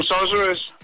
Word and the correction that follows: Scorsese: Ja, den er også lidt svær at sige det italiener navn, Det er Mar- Martin Scorsese.